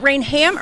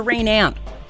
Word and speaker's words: Rainham: Is it Rainham or Rainam? – Rainham. – Rainham. – You pronounce the H.